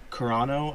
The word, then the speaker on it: Carano: So Pedro Pascal, Gina Carano, I believe is how you say her name, and Carl Weathers.